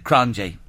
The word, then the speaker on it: Cronje: It's C-R-O-N-J-E. – Yes. – And she says to me, how do you pronounce it? And I said, Cronje. Wrong. Cronje. – That's right, yes.